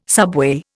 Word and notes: subway: Indian

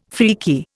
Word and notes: freaky: Indian

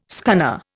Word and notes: scunner: Indian

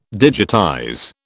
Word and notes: digitize: British English

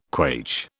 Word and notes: quaich: British English

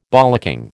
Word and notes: bollocking: American English

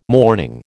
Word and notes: morning: American English

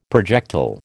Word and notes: projectile: American English

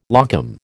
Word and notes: locum: American English

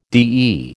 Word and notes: DE: American English

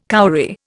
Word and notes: cowrie: Indian

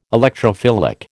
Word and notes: Electrophilic: American English

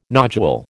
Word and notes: nodule: American English

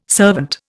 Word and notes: servant: Indian

